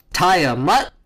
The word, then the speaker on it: Tiamat: Because Thanos did not know about Tiamat. – Tiamat.